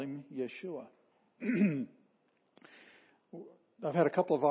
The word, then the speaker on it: Yeshua: And so by the time Jesus came and lived among us, the name had been shortened to Yeshua. Yeshua. And to this day, and over here, the capital letters, that's the accented syllable.